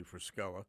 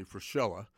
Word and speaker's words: Fruscella: I played that track as a little bit of an introduction to Tony Frischella, or Tony Frischella. I know a friend of mine calls him Frischella because the name is spelled F-R-U-S-C-E-L-L-A.